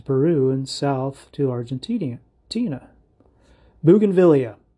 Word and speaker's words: Bougainvillea: Bougainvillea, you do want to stress on the third syllable, the V syllable. Bougainvillea.